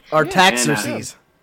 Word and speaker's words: Artaxerxes: Nehemiah has gathered up the nations of Israel as they were scattered across the land, and King Artaxerxes was his king at the time, and his name right. – Did I say it right? I did. – Artaxerxes.